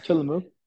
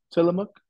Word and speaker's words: Tillamook: Is that how it's pronounced? – I don't know. I'm probably saying it wrong. What would you say? – Tillamook. Tillamook. – That sounds better. Tillamook.